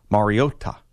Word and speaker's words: Mariota: Well, he pronounces it the way that Mariota pronounces it, which is Mariota.